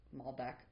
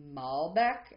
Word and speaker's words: Malbec: It's interesting because in the Wine Bible it says that in France it's kind of more pronounced Malbec, and in Argentina it's Malbec. – You were like, so more accent, emphasis on the bec.